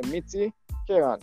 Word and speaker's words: Mitti Ke Rang: Mitti means mud and Ke Rang means colors. So it's the colors of the mud. Colors of the mud. It's Mitti. – Oh, okay. Mitti Ke Rang.